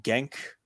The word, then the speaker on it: Genk: Olympiakos take on Genk. I think I pronounced that correctly. Right, Chris? – Yeah. – we take on Genk in the third qualifying round of the Europa League.